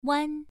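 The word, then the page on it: wan: tone 1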